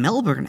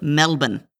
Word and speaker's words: Melbourne: I think in Melbourne, actually. No, I'm sorry. – Yep. – Melbourne. I was told that's how you pronounce that.